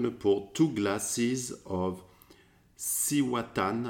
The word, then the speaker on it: Cihuatán: No, the accent's on the A at the end. So, I think for my Spanish lessons, it's like Cihuatán. – So, Cihuatán. I am pouring two glasses of Cihuatán, which is an edge rum that we purchased while we were traveling to Salvador.